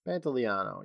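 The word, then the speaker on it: Pantoliano: Pantaleano, yeah.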